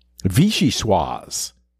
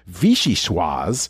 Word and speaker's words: vichyssoise: According to the Food Lover's Companion, vichyssoise is a rich, creamy potato and leek soup that's served cold, garnished with chopped chives. In the United States, it's often mispronounced vichyssoise.